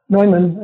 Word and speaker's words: Neumann: Neumann, I guess is the proper pronunciation, who became the first California distributor for Porsche.